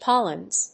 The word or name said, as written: pollens